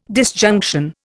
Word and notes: disjunction: Indian

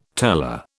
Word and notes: teller: British English

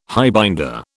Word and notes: highbinder: British English

